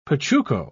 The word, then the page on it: pachuco: British English